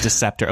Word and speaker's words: Deeseptor: It's Deceptor. Okay. It's spelled D-E-E-S-E-P-T-O-R.